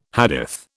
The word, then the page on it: Hadith: British English